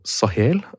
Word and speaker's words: Sahel: Du har en årrekke nå arbeidet med spørsmål knyttet til krig, konflikt og samfunn i Nord-Afrika og Sahel. Er det riktig uttale? – Ja.